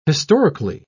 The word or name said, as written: historically